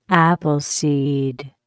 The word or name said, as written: Appleseed